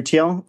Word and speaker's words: Thiel: Peter Thiel for a position.